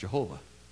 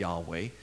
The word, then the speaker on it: Yahweh: So one thing we're pretty sure is it's not pronounced Jehovah. Our best guess is it's probably pronounced something like Yahweh. And we borrow some of that from Hallelujah, because we recognize that means praise Yah, or Yahweh.